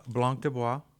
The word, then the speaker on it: Blanc de Bois: That is not how you say that. – Okay, how do you say it? How do you say it? – That is Blanc de Bois, Syrah, Merlot, and Caminera, New York.